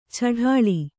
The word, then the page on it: ಛಢಾಳ: chaḍāḷa